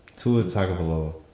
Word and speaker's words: Tagovailoa: Tua Tagovailoa.